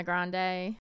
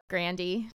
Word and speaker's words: Grande: And Ariana Grande. – Is what? – Grandy. – Oh, well, she did that on purpose.